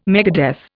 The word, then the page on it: megadeath: Indian